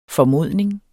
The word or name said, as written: FORMODNINGS